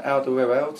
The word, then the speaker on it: Alderweireld: Money, I think Pochettino has just brought in that Alderweireld. Is that how you pronounce it? – Yeah.